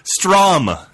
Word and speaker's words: Strom: How do you say it? – Strom. – Strom? – Yeah.